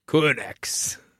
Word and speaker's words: Qurunx: So, okay, I have no idea how to say this. – Yeah. – Q-U-R-U-N-X energy. – Quinx. – Quinx energy under the master's control and the power of the doctor.